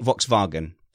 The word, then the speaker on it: Volkswagen: v- Volkswagen.